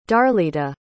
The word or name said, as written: darlita